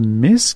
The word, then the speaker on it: MISC: On va entendre l'excellent trio de jazz contemporain montréalais Miss. Qu'est-ce que je prononce bien? – Oui. – M-I-S-C, dont l'album Beat Bouquet.